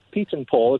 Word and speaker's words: Pyatenpil: it's pronounced Pyatenpil. Pyatenpil. It's a Dutch name.